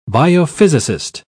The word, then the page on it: biophysicist: British English